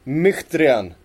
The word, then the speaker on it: Mkhitaryan: Eh, och nu verkar ju Mik Mkhitaryan, nu var jag faktiskt inne och, och kollade upp hans uttal och han heter inte alls Mkhitaryan, han heter jag tänker inte ens ge mig på hur det ska uttalas. – Myktrian.